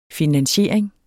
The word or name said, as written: finansiering